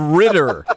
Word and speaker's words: Ridder: You just called him Riddler instead of Ritter.